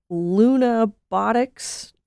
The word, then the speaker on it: Lunabotics: Steve, I was gonna ask you about the NASA Lunabotics Challenge. I think I'm saying that right. Can you tell me about it? – That is correct, and that's what Oliver was talking about.